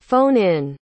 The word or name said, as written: phone-in